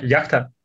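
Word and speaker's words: Yachta (яхта): Yachta.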